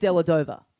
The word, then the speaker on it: Dellavedova: He then goes, yeah, but his last name is Dellavedova. – Dellavedova. – Dellavedova. And I go, what? And he goes, when you introduced me to him and saying he was from Maryborough, he said, I'm Carl Dellavedova.